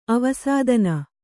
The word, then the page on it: ಅವಸ್ಥಾನ: avasthāna; Kannada